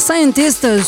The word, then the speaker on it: SCNTST: que l'on doit à Scientist. Ça s'écrit S-C-N-T-S-T sur le label allemand Boys Noise.